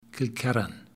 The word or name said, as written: Kilkerran